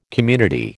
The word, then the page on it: community: American English